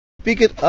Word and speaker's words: picked: Picked up her kit and headed for work. – Picked up her kit and headed for work. All right, picked is one syllable. The ED just becomes a T. But headed is two syllables.